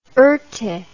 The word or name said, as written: erte